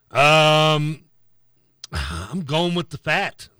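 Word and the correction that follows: Pfaadt: That's what it's pronounced, right? – Fought. – Fought.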